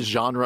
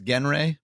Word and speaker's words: genre: For the most part, I love the genre of sandwich. How does that sound? And so, I believe that's pronounced Genre.